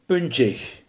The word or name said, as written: puntig